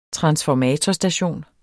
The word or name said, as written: TRANSFORMATORSTATION